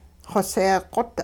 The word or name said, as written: xoseeqot